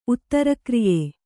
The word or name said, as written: ಉತ್ತರಕ್ರಿಯೆ